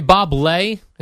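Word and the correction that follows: Bob Ley: Is that how you say his name? – Are you serious? Bob Lay? – L-E-Y. – Please tell me that's a joke. – Bob Lee. Lay. Lee? – Bob Lee.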